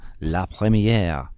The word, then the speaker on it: La Première: This includes the highly impressive new La Première first class routes. Or sweet. – Very good. – There we go. – Very impressive. Good pronunciation there.